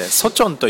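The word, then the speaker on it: ソ チョ ン (서촌): 西側に広がっている町なんですけれども、西の村と書いてソチョンというエリアにあたります。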